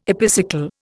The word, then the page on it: epicycle: Indian